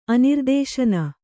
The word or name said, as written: ಅನಿರ್ದೇಶನ